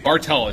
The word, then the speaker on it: Bartal: Bartell, it's Hungarian. – Bartell. Oh, are you Hungarian? I'm Hungarian, too. – Hungarian. B-A-R-T-A-L.